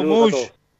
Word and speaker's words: Mouche: Chao, Mush. Suerte. – Muy bien. Chao. Pablo, Pablo como decía Carlos Bianchi pronunciándolo en francés.